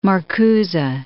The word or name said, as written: Marcuse